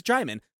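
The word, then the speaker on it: Djimon: So, yeah, every single time that, like, Russell, like, anyone else met him on set, Russell Crowe, like, oh, yeah, this is Jaimon, like Simon, like, to let everyone know, like, this is how he pronounces his name, like, try to get it right.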